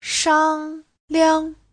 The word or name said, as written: shang1liang5